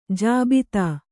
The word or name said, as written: ಜಾಬಿತಾ